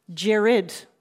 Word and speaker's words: Gerard: Gerard Butler. – That is correct, yes. And just so everyone knows that might be listening, yes, we have heard that his name is actually pronounced Jared.